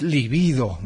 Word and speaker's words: libido: Yo anduve diciendo líbido. Y es libido, me corrige bien Victoria. Además lo escribe libido, va sin acento, va con B larga, es la de Freud. En cambio, líbido, va con acento en la primera I y va con B corta.